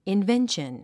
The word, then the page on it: invention: invénʃən; インベンション